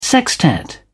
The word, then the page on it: sextet: British English